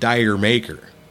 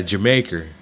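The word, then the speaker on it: D'yer Mak'er: And the other song I really don't like is, and I had to look at this because I've always pronounced it Dyer Maker. – You did? – Yeah. – Jermaker. – Because that's, yeah, Jermaker. And yeah.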